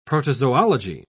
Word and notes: protozoology: British English

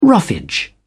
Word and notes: roughage: British English

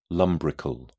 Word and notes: lumbrical: British English

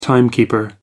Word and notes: timekeeper: American English

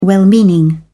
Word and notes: well-meaning: British English

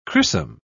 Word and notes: crissum: British English

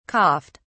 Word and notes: coughed: British English

